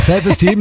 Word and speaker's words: Self Esteem: The Offspring, Self Eastern. – Ja, die Aussprache ist ein bisschen... – Steam, ist Steam. – Ja. Ja, ist richtig. – Ja, Self Esteem nehmen wir auch.